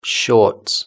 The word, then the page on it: shorts: British English